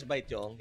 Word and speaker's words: Baetiong: How do you pronounce that? – Filipino way is Baetiong.